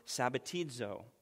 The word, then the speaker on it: sabbatizo: sabbatizo. That's spelled S-A-B-B-A-T-I-Z-O, sabbatizo.